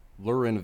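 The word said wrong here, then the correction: Lurelin: Village. – Yeah, Lurlin. Yeah, Lurlin.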